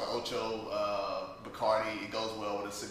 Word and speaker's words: Bacardi: So, well, one, they don't even, it's not Bacardi, it's Bacardi is how they pronounce it out there.